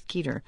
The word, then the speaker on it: Keeter: Our guests are Scott Teeter, the Associate Director of the Pew Research Center, and I have to apologize to Scott because I have been mispronouncing his name. It's Keeter, not Teeter. And also joining us,